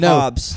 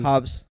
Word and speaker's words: Hobbs: No, Hobbs. – Hobbs, Texas. – Hobbs. One syllable. – Fucking Hobbs, Texas, okay? – Hobbs, Texas. – H-O-B-B-S, Texas.